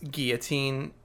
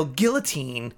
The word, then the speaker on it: guillotine: He's like, look, it's a real guillotine. And then he gets puked on. I'm like, yeah, you should learn how to pronounce it. – Those L's are silent, you turd.